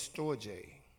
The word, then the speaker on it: storge: One of the loves is storge. You spell that S-T-O-R-G-E, storge. It's a Greek word.